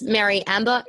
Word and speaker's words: Meri Amber: My name is Meri Amber.